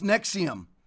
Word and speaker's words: NXIVM: called NXIVM, pronounced Nexium.